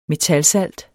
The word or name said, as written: metalsalt